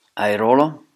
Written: Airolo

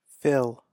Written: Fill

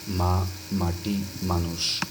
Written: Ma Mati Manush

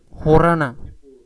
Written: Horana

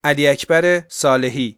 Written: Ali Akbar Salehi

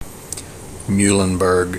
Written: Muhlenberg

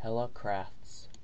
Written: Helle Crafts

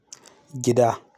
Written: Gida

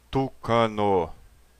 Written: Tucano

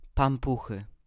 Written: Pampuchy

